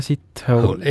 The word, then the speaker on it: Sithole: Jaja Sithole. – Ja. – Kuidas niimoodi tundi? Hääldataksegi? – No, kirjutatakse S-I-T ja hole, nagu ingliskeeles on see.